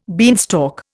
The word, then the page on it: beanstalk: Indian